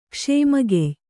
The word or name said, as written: ಕ್ಷೇಮಗೆಯ್